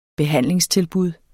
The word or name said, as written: behandlingstilbud